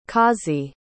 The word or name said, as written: kazi